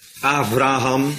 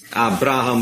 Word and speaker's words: Avraham: Avraham, he? Avraham Soetendorp is dat, hè? Avraham. Abraham mogen we niet zeggen.